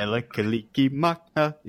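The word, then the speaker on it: Mele Kalikimaka: Melakalikimaka is the thing to say.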